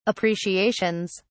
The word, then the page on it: appreciations: British English